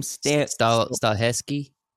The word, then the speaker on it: Stahelski: So the John Wick director, the director for John Wick, Chad, how do you pronounce that last name? – Stahelski.